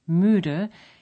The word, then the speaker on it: müde: To pronounce a U umlaut, as in müde, you simply say a long E and round your lips again. Like this. I-ü.